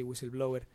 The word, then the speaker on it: Whistleblower: Es así, no sé si se pronuncia así, Whistleblower. – Sí.